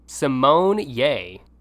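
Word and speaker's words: Symoné: The actress posted a TikTok revealing that the proper way to say her last name is Simone Ye, like yay.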